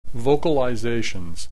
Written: vocalizations

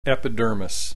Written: epidermis

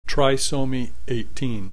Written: trisomy 18

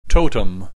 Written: totem

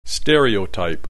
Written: stereotype

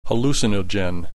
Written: hallucinogen

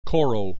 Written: koro

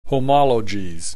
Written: homologies

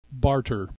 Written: barter

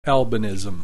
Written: albinism

